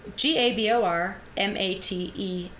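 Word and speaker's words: Gabor Maté: And Gabor or Gabor Mate? – Gabor Mate, accent aigu. – Rhymes with latte.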